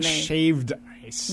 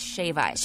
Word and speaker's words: shave ice: It's not shaved ice. – No, it isn't. – Now that we're talking about pronunciation on this podcast. – Yeah. So let's be correct. Let's be articulate. It's shave ice.